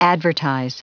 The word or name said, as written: advertise